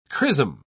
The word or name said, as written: chrism